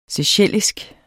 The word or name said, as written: seychellisk